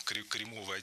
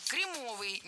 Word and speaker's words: кремовый: Кремовый, неверно, правильно, кремовый. Ошибка досадная, возможно, это даже оговорка. Но просто я никогда раньше не слышала, чтобы так говорили.